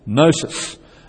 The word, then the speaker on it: gnosis: It's based on the same root, knowledge, gnosis. You don't pronounce the G.